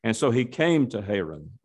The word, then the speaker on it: Haran: It occurred before Abram lived in Haran, or Haran, as it is sometimes pronounced.